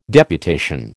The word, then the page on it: deputation: American English